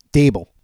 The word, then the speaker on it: Daboll: I know you're covering a lot of them, but Brian Daboll, is it Daboll or is it Dable? I feel like I always say it wrong. – Dable.